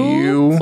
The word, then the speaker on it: Pugh: Pugh. – Pugh.